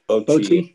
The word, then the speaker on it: Bochy: Bochy.